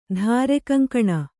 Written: ಧಾರೆಕಂಕಣ

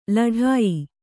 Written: ಲಢಾಯಿ

